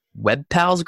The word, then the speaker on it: WebPals: Cody is partnership manager at WebPals Group. Is that how you say it? – That is right. Yes.